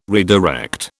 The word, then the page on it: redirect: British English